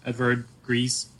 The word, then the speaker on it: Edvard Grieg: Edvard Grieg. That's how you say his name. – I don't know. I believe so.